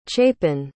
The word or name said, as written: chapin